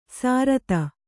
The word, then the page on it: ಸಾರತ: sārata